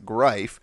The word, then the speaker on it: Greif: You pronounce it Greif. If you look at his Twitter, he actually says, pronounce it Greif and we'll be just fine.